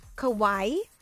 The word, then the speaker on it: kawaii: So the options that you get are anime, stylish, light, cosmic, fantasy, kawaii, pop, iridescent, and fairy princess. – Kawaii isn't like Hawaii? – I think, you pronounce it like that, but I think it's more of a Japanese style, and I think it means cute.